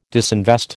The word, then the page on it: disinvest: American English